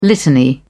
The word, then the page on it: litany: British English